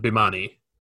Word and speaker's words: Bemani: Is it Bemani? Did I say that right? – I always pronounce it Bemani. – Bemani, that's probably right.